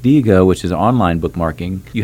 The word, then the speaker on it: Diigo: D-I-I-G-O? – D-I-I-G-O. And somebody may correct me, but I'm pretty sure that's the pronunciation, Digo.